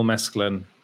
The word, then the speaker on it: Mescal: Like, I actually had a bit of an argument with someone on Twitter about Paul Mescalin. Paul Mescal, I get his name wrong all the time.